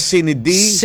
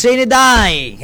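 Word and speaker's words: sine die: Sinidai. – What is the right pronunciation? – Sinidai actually. Sai. – Sai. – Sinidai. – The spelling is S-I-N-E. – N-E. – Another word, die, D-I-E. It's D-I-E.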